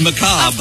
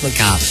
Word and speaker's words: macabre: It's actually macabre. – Of course it's macabre. – Yes, like, it's I got corn on macabre. Yeah. Well, you pretty good. That's the way you know you can pronounce it correctly.